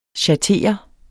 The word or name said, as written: schattere